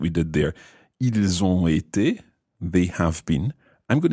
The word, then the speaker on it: été: So the past participle of être is été.